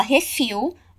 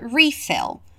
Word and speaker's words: refill: A palavra refill você já conhece com a pronúncia do português, né? Aqui a gente fala refill, lá a gente fala refill.